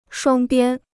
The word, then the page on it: 双边: shuāng biān